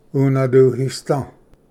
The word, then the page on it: ᎤᏅᏚᎯᏍᏓ: unvduhisda